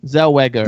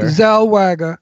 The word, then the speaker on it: Zellweger: That Renee, how do you say her last name? – Zellweger. – Zellweger.